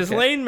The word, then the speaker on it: Ghislaine: Ghislaine